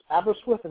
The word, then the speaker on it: Aberystwyth: So, I'm sure they have many better things to do over in Aberystwyth, is that how it's pronounced? Yes,